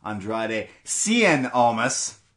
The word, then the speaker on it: Andrade Cien Almas: Johnny Gargano versus Andrade Cien Almas, the proper way you say his name, because Gargano versus Almas and chill.